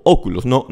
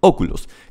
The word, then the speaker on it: Oculus: su otra empresa que se llama Oculus, con acento en la O, Oculus, Oculus.